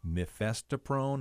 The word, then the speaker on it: mifepristone: The Supreme Court's been dealing with mifepristone.